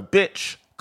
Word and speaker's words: Byczkowski: So Byczkowski as in and ski as in skiing.